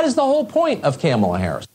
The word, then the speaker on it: Kamala: Her name is pronounced comma, like the punctuation mark. – Comma. – Kamala.